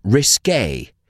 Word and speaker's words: risqué: Se pronuncia risqué.